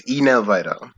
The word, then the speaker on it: Henel Vidal: It's Enel Vidal. So the H is silent.